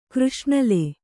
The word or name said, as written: ಕೃಷ್ಣಾಲ